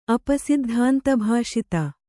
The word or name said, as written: ಅಪಸಿದ್ಧಾಂತಭಾಷಿತ